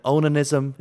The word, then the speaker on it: onanism: You know, the sort of sense of like the onanism. Is it onanism?